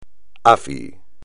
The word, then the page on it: AFI: espagnol